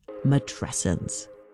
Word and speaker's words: matrescence: And then one day she came across a word that seemed perfect, matrescence. – And you pronounce it like adolescence.